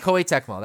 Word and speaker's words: Koei Tecmo: No, it's Koei Tecmo? Tecmo? Koei Tecmo? – Koei? – Yeah, Koei Tecmo. Sorry, I didn't pronounce it right for whatever reason, but Koei Tecmo, that's correct.